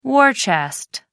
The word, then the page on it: war chest: American English